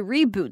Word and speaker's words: reboot: 那 reboot 这个字你要稍微注意一点，因为 reboot 在这边它是一个名词，所以你要念成 reboot 哦，就不会是动词的 reboot，所以重音放在哪里你要注意一下。